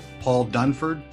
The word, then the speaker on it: Paul Dunford: I have with us today Paul Dunford.